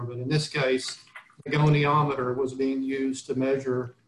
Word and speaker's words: goniometer: How do you pronounce that? – I pronounce it goniometer. – Goniometer. – It's okay. – Possible that I'm mispronouncing it, but my clients, I believe, pronounced it that way.